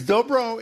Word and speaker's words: dobro: Is dobro, is that, do I pronounce that right? – That's it.